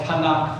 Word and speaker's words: TaNaK: And it's actually, they call it TaNaK for short. They organize it differently than we do. T-A-N-A-K.